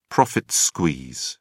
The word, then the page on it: profit squeeze: British English